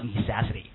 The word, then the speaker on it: necessity: 前面跟哪个？Of 两个并列，跟哪个？Necessity 要读作 necessity 啊，形容词叫 necessary，名词 necessity， right？